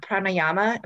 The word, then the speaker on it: pranayama: Because it's like, you know, I'm sure you teach this in yoga, the pranayama. Am I saying that right? – Pranayama. Yes, pranayama.